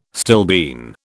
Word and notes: stilbene: British English

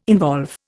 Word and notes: involve: Indian

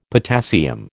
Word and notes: potassium: American English

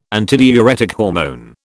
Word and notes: antidiuretic hormone: British English

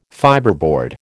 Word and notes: fibreboard: American English

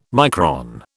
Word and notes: micron: British English

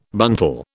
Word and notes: buntal: British English

